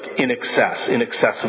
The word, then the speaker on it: INXS: And the group is INXS, I-N-X-S, pronounced like in excess of something.